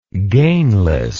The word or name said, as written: gainless